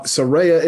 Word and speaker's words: Soraya: We can't say Soraya, even though that makes more sense for a lot of people for some reason. Um, Soraya is apparently the correct correct pronunciation.